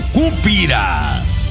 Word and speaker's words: Cúpira: acento en la U, Cúpira, estado Miranda, tras haber salido del penal rodeo 3, el pasado viernes.